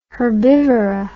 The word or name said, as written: herbivora